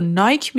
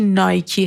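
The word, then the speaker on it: Nike: و انگلیس شما این برند رو نایک میشنوید اما در امریکا بهش میگن نایکی و از اونجایی هم که این برند یه برند امریکایی هستش و خود بنیانگذارش تاکید کرده تلفظ صحیحش نایکی هستش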